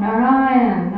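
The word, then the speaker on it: Narayana: "Narayan, Narayan, Narayan, Na, Ra, Ya, Na." Four syllables.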